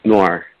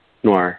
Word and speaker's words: Noir: Noir. Noir.